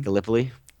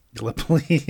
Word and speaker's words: Gallipoli: I mean, this is – obviously, this is the – I don't know, Gallipa, how do you say this fucking thing? – Gallipoli? – Gallipoli, yeah.